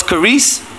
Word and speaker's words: charis: In the New Testament, the word is charis, C H A R I S.